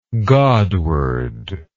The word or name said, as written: godward